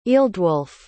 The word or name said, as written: ealdwulf